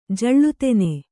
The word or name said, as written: ಜಳ್ಳುತೆನೆ